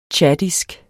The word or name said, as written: tchadisk